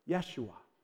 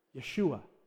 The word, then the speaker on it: Yeshua: But there's no J in Hebrew, so it's actually pronounced Yeshua, Yeshua.